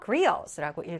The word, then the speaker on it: griots: 자, 요 T는 발음을 안 하더라고요. griots라고 읽어요.